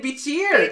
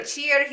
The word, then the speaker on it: Bichir: Damien, how do you say his last name? Damien Bichir. – Bichir, he played Detective Goodman.